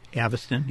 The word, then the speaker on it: Avastin: So in the initial study that was done, is it Avastin? Is that how it's pronounced? – Avastin, yeah.